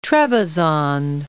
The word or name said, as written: Trebizond